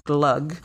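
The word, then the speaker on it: glögg: Glog, G-L-O-G-G, is actually pronounced glug, mulled wine, and there are saffron buns, yulskinka.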